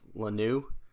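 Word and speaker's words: Luhnow: And as a result, AJ Hinch and Jeff, I think it's Lanou, that's how you pronounce it,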